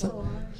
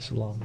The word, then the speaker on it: Solange: I believe it's Losing You by Solange. How do you pronounce Solange. – That? Solange.